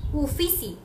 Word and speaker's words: Uffizi: Este está en el este, Museo de Ifisi. Siempre lo digo mal porque es Rix, Ifisi. déjame se los digo bien. Uffizi, U FF y ZI, Fisi, en Florencia.